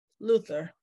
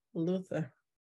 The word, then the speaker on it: Luther: Luther. – You ain't say his name right. – Luther.